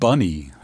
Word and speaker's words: Bnny: Keeping it dreamy for the second half of All Songs Considered, I have the music of Bunny, that's spelled B-N-N-Y, which is Jessica Vicious and her twin sister Alexa and some dear friends.